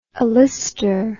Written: alister

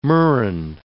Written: murrain

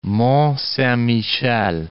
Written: Mont-Saint-Michel

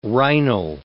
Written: rhinal

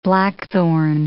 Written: blackthorn

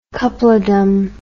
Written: coupledom

